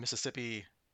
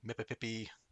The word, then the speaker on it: Mississippi: When Dargo explains he doesn't have a timekeeping device, Crichton explains to him the one Mississippi... two Mississippi method, although Dargo kind of mispronounces it as one Mippippippi, two Mippippippi.